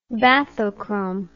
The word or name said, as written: bathochrome